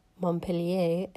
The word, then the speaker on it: Montpellier: We're recording this episode from our home in the south of France near Montpellier, or Montpellier, as I have learned that it's the correct way to pronounce it.